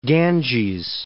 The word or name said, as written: Ganges